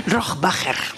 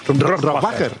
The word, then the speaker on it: Rohrbacher: Rohrbacher. – Rohrbacher.